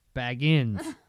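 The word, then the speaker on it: Bagans: Are we done with Zach Bagus now? – Baggins. If you're going to say his name, get it the fuck right.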